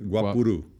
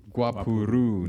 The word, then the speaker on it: guapuru: Did I say it right this time? – No, almost. Guapuru. – Guapuru. – Guapuru. – Okay. – Yeah. – I got the right emphasis, but I put they inverted the two.